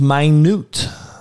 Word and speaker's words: minute: But the next word is spelt the same, but it's pronounced minute.